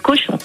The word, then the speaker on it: Cauchon: L'évêque cochon. – Oui, mais ça s'écrit C-A-U-C-H-O-N, bien sûr.